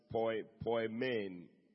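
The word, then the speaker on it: poimen (ποιμήν): And it's spelled P-O-I-M-E-N, but you pronounce it poimen, poimen, P-O-Y. M-A-N-E, which is translated pastor.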